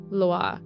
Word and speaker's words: Loa: It's Loa. So it's like, it's pronounced like LWA.